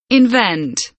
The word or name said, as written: invent